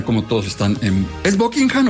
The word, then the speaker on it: Buckingham: Es Buckingham. Eso y de hecho, inglés perfecto, el H medio que se es medio silencio. Buckingham.